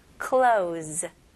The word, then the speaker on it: clothes: 원어민들은 일단은 똑같이 clo로 시작하다가 z로 마무리합니다. 그렇게 한번 해볼게요. close. 너무 쉽죠? close, z, z 발음 나와야 돼요.